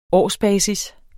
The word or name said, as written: årsbasis